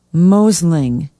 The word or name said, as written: Mosling